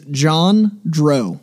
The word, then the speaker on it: Jondro: It is Jean-Drew. – Jean-Drew. – So it's like J-O-N-D-R-O.